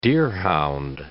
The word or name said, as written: deerhound